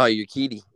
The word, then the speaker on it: Urquidy: Jose Urquidy, I think is how you pronounce that last name. – Oh, Urquidy, yeah.